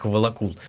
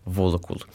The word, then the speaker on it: Волокул: В коми-языке ударение всегда падает на первый слог. Поэтому некоторые слова, например, вот название деревни, которое хочется прочитать как Волокул, читать так неправильно. Нужно называть его Волокул.